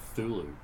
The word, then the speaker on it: Cthulhu: You know that Cthulhu, the sea is silent. It's just Thulu when you pronounce it.